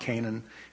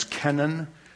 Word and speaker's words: Kenan: It's in most of your Bibles, it's probably pronounced or translated as Canaan. It's not Canaan. It's a different word again. It's Kenan.